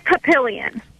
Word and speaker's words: Papillion: Hey, pronounce the name of the town you live in, please. – It's Papillion.